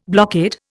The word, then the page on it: blockade: Indian